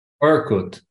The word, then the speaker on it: Orkut: How do you pronounce it? Orkut? – Orkut.